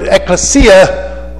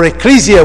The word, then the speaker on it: ekklesia: Greek word, ekklesia, or ecclesia, whichever you want to pronounce it, whichever way you want to pronounce it.